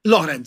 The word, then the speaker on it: Loren: Spricht man sie auf Italienisch Loren aus? Weil ich sage immer Sophia Loren. – Loren sagt man.